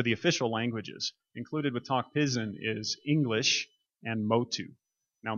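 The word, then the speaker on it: Tok Pisin: It's pronounced Tok Pidgin in Papua New Guinea.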